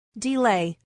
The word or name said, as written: delay